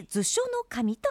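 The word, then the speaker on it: 図書頭: 図書の頭と書いて図書の神と言います。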